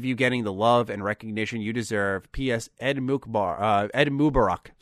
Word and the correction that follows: Eid Mubarak: So I wanted to come back to this part here after a night's rest and make sure I fixed my pronunciation and say Eid Mubarak to anyone in our audience who is celebrating.